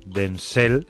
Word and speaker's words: Denzel: Denzel eh, con acento en la, en la L, eh, que es eh, un peliculón.